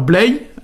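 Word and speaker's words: bleiz: Alors Blay, on va avoir Blazy, hein. C'est pour ça qu'il y a un Z à la fin de Blay, hein. Il se prononce pas, mais il sert au pluriel, hein.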